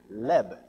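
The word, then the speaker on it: leb (לב): The word heart in the Hebrew is the word leb, L-E-B. That's how we would pronounce it.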